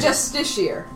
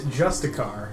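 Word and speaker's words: Justiciar: There's an I between the C and the A. – A Justice Seer. That's how you pronounce it.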